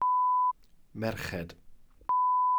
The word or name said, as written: merched